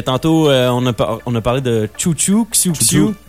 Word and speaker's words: Xiu Xiu: Se prononce chouchou. Donc, mais ça s'écrit X-I-U-X-I-U.